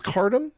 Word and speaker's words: Khartoum: I think it's pronounced Khartoum. – Khartoum. – K-H-A-R-T-O-U-M.